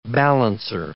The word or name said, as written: balancer